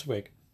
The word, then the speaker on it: Wiig: Directed by Paul Feig, produced by Judd Apatow, Barry Mendel, Clayton Townsend, written by Annie Mumololo and Kristen Wiig. Hopefully I pronounced that somewhat right. – Yeah, it's Wiig.